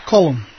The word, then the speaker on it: Colum: Colum, as in the Irish pronunciation.